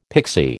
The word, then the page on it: pixie: American English